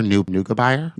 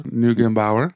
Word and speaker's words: Neugebauer: I feel like I just said his name wrong. Gaber Neugebauer? – Bauer. Neugebauer.